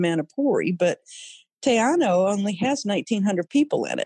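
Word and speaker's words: Te Anau: And Teanao is tricky to pronounce, but if people want to look it up, it's capital T, small e, one word. And then the second word is capital A-N-A-U, Teanao.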